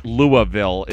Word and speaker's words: Louisville: By the way, it is L-O-O-U-H, Louisville, if you're wondering. That is how it's correctly pronounced.